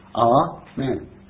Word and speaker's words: Amen: Well, Samuel Miller, who was a great theologian and scholar, he says that Amen is the only two-syllable word in the English language that the accent is on both syllables, Amen.